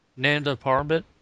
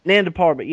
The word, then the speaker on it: Nanda Parbat: I always butcher how it's pronounced. Nabat Parbat, you know, the Himalayan. – Nanda Parbat? – Nanda Parbat, yeah, the Himalayan stronghold.